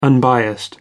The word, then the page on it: unbiased: American English